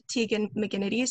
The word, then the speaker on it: Tegan McGinnity: It's my full name, at Tegan McGinnity, so I'll spell it because nobody can ever spell it right, especially Tegan.